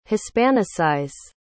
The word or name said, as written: hispanicize